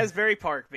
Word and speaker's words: Asbury Park: Joey Janela defeating Nick Gage um, at the in a As Asbury Park. Am I pronouncing it correctly? – Asbury, my Jersey friend? Asbury Park, baby.